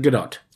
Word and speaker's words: Gadot: She pronounces it Gadot.